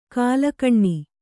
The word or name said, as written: ಘಲಕನೆ